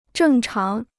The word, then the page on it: 正常: zhèng cháng